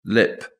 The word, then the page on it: lip: British English